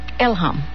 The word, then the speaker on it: Elham: Is it Elham or Elham? – Elham. – Elham. Elham. They add emphasis on the first syllable.